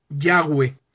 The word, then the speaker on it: Yahweh: que la pronunciación más exacta, si pudiésemos referirnos a ellos como cuál pudiera ser la pronunciación más correcta, bueno, pues es Yahweh.